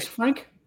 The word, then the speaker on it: Lisfranc: Lisfranc? That's how you say it? – Mm-hmm.